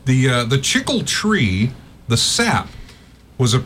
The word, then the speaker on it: chicle: Did you know, Chiclets, do you know where they get their name from? – No. – I guess it's pronounced Chicle. Chicle.